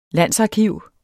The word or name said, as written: landsarkiv